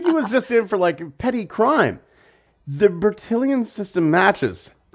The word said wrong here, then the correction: Bertillon: I think you're throwing an extra I in there, which is easy because you've got a lot of I's and L's and it's French. – Fair enough. Bertillon.